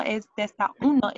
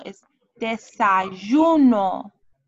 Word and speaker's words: desayuno: Oh, desayuno. – No, es desayuno, es desayuno.